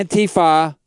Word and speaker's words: Antifa: Antifa? – Yeah, Antifa.